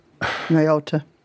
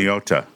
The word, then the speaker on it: Nyota: Nyota. – Nyota? Is that how you pronounce it? – Nyota.